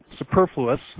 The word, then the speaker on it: superfluous: But 2 Corinthians chapter 9, Paul writes, now it is, I can never say this right, superfluous, right? Yes. All right, good.